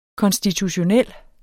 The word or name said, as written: konstitutionel